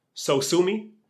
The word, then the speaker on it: Sosumi: So they, the 1991 Macintosh Alert, the name is Sumo, and it's pronounced Sosumi.